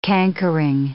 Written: cankering

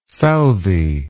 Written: Southey

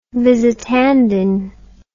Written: Visitandine

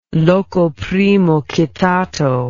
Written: locoprimocitato

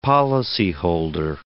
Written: policyholder

